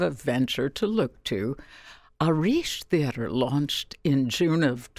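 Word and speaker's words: Arís: Arish is spelt, first of all, A-R-I-S, and there's an accent on the I.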